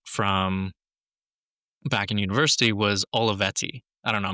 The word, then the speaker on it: Olivetti: give me the Italian version. – Olivetti. – Okay, that's way better.